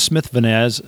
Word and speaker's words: Smith-Vaniz: My last name is Smith-Vaniz. It has a hyphen, and then it's V-A-N-I-Z.